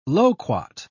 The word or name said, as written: loquat